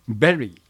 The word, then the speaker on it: bury: 発音で 2 つほど、これも題名ですが、 bury、葬る、埋葬するというのは bury で、 bury、 berry、いちごと同じ発音になります。